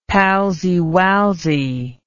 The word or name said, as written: palsywalsy